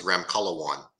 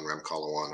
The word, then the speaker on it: Ramkhalawan: Uh, last name is Ramkhalawan. Uh, my name is Dr. Alan Ramkhalawan.